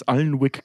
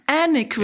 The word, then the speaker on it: Alnwick: Das Schloss heißt übrigens Allenwick Castle. – Mhm. – Also in echt. – Anik wird es ausgesprochen.